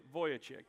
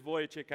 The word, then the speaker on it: Vujicic: That's how you say that. I mean, you cannot say this phonetically. I had to practice this for like a half an hour to get it. But it is Vujicic.